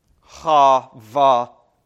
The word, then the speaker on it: Habakkuk: So how would we pronounce this in Hebrew? We'd pronounce the prophet's name Habakkuk. Habakkuk. Okay? And if you want to really pronounce it well in Hebrew, you've got to give that first letter a ch kind of sound.